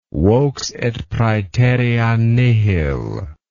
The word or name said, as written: vox et praeterea nihil